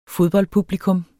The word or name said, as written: fodboldpublikum